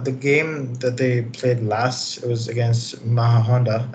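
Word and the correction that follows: Majadahonda: I think that's how you pronounce their name. – Yeah, Majada Honda. I think they got the extra D in there. – Oh, there's a D in there? – Yeah.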